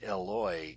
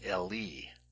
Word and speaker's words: Eli: So instead of Eloi, it should be Eli. – Okay. – And Eli literally means my God, but the Hebrew name for Elijah was Elia.